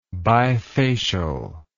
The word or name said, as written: bifacially